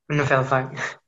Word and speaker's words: Nouvelle Vague: Nouvelle Vague. – Yeah. – I don't know how that would be pronounced. – Nouvelle Vague.